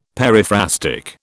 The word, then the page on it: periphrastic: British English